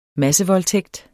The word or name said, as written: massevoldtægt